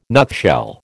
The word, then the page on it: nutshell: American English